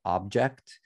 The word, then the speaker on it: OBJKT: The other big thing about Tezos that really stands out to me is the NFT marketplace called Object, that is spelled OBJKT. So Object with funky spelling.